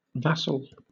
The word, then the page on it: vassal: British English